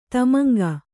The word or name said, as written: ಧೂಮಾಂಕ